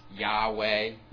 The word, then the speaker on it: Yahweh: We think it's pronounced Yahweh, but it's normally rendered Lord in all caps or Jehovah.